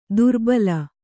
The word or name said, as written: ದೋರ್ಬಲಿ